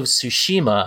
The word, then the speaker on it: Tsushima: Ghost of Tsushima, I'm guessing that's how you pronounce it, because I had a second-grade teacher named Mrs. Tsushima. – That is correct.